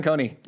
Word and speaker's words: Kohne: It's a callback to the first time we realized we'd been mispronouncing his last name for 10 years. – Yeah, Shawn Kohne. Yeah, we did forever. Coney Island. – Yes. – And I don't know why we added the E sound.